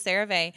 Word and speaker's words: CeraVe: He uses CeraVe.